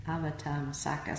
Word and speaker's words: Avatamsaka: The inner being that he chose, that word, was taken out of the Avak Avatamsaka Sutra. Did I say that right? Close. Avatamsaka Sutra.